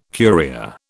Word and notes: Curia: British English

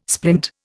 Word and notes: splint: Indian

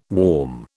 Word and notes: warm: British English

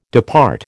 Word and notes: depart: American English

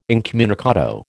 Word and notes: incommunicado: American English

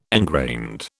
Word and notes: engrained: British English

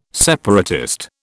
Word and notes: separatist: British English

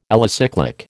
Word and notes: alicyclic: American English

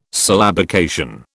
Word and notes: syllabication: British English

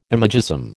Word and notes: imagism: American English

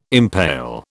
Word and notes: impale: British English

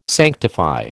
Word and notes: sanctify: American English

